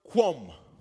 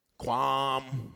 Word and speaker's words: qum: The word stand in Hebrew is the word quum, spelled Q U M. Uh, watch this, Pronounce uh, K O O M. So, depending on where you're at in America, you're going to say that several different ways.